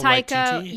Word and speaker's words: Taika Waititi: And Taika Waititi. Did I say that right? – Taika Waititi. – Yeah. – He did yeah.